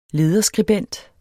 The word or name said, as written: LEDERSKRIBENT